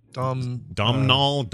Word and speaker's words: Domhnall: Doomnall Gleason, however you say his name. – Dom Knoll.